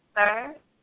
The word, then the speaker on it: star: And level is star, which is S-T-A-R-A, but it's pronounced almost like how you would write S-T-E-R, sir. So you're not pronouncing it. Don't pronounce it star. It's actually sir. S-T-A-R-A, sir.